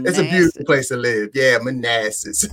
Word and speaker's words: Manassas: Yeah, Manassas, good old Manassas.